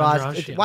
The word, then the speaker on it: Andrade: And the fight of the night was Andrade against Hill. Am I saying her name right? – Yeah, Jessica Andrade.